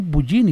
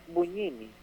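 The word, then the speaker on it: Bugnini: Monseñor Bugini fue que dijo, ¿verdad? – Buñini.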